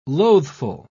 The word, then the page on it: loathful: British English